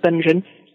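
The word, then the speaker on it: Będzin: That's how you pronounce what looks mm-hmm. like Bedzin to you and me, but it's pronounced Benzin.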